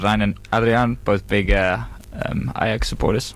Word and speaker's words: Ajax: So the background of this is the two belligerents in this battle were the I'm going to say Ajax because my uncultured New Zealand upbringing would lead Ajax. – Me to do so. How do you say that again? – Ajax.